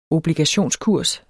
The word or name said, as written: obligationskurs